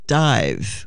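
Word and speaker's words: Dàimh: We're listening to the band Dame. It's actually, you pronounce it in Scotch Gaelic, it's pronounced dive, but it's spelled D-A-I-M-H.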